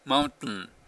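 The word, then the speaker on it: mountain: 実際にどういう現象を、よく世間で、 T を発音しないって言ってるかっていうと、マウンテンとかキャプテンっていう言い方をするから、 T っていうの聞こえないですよね。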